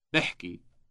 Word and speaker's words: بحكي: بحكي. بحكي. – Note the initial B sound.